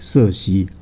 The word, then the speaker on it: 瑟西: 对, 对，那因为呢在英语系的国家里面呢，把这个 C 啊读成为 K, 克，所以翻译名称有些不一样，但现在来讲呢，多半我们把它翻译成为瑟西啊，这样是比较清楚。 – 因为它的是 C-I-R-C-E, 因为我们的翻译就是 Cersei。